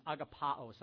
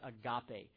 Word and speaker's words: agapao: You know, the Greek word for love is agapao. Sometimes we pronounce it agape.